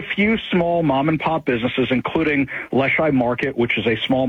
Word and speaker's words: Leschi: What happened in the Leschi neighborhood of Seattle? – Sure.